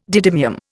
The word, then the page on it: didymium: Indian